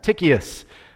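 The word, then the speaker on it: Tychicus: So first of all, you get this introduction to a guy named Tychius, and that's how you pronounce that. Tychius.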